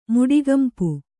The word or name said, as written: ಮುಡಿಗಂಪು